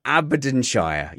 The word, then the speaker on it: Aberdeenshire: So Aberdeenshire.